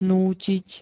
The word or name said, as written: nútiť